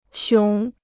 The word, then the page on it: 熊: xióng